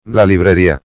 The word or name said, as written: la librería